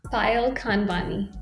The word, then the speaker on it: Payal Khanwani: Payal Khanwani.